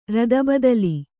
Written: ರದಬದಲಿ

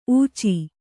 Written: ಊಚಿ

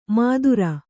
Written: ಮಾತುರ

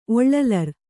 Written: ಒಳ್ಳಲರ್

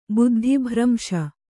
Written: ಬುದ್ಧಿಭ್ರಂಶ

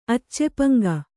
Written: ಅಚ್ಚೆಪಂಗ

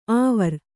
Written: ಆವರ್

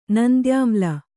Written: ನಂದ್ಯಾಮ್ಲ

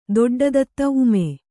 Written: ಊಮೆ

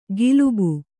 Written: ಗಿಲುಬು

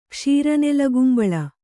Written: ಕ್ಷೀರನೆಲಗುಂಬಳ

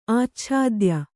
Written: ಆಚ್ಛಾದ್ಯ

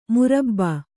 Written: ಮೊರಬು

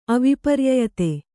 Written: ಅವಿಪರ್ಯಯತೆ

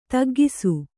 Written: ತಗ್ಗಿಸು

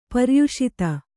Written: ಪ್ರಯಸ್ತ

